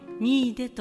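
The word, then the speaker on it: 新出: 新しいの新という字に出ると書いてにいで」と読む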